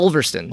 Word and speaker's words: Ulverston: So you said Ulverston? Is that how you pronounce it? – Yeah, Ulverston, England.